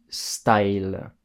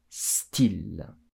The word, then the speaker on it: style: Y wa I to hatsun sarete imasu Style il ne se prononce pas style comme en anglais, mais bien style.